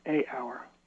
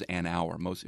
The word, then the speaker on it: hour: Hour starts with a vowel sound, even though it's got an H in the writing. And so most people, I would say the correct usage is an hour.